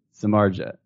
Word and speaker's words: Samardzija: This is really tough. – Samardzija. Now. – Samardzija. – I feel like there's a subtle fourth syllable in there.